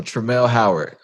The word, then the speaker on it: Tramelle Howard: So Tramelle Howard, I pronounced your first name correctly, right? – That is correct.